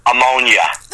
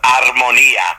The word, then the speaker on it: Armonia: I mean, if you're Italian and you know a little bit of the language, it's pronounced armonia. But the Americans always said, hey, ammonia, ammonia, ammonia. They never saw the R in armonia.